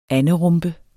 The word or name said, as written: ANDERUMPE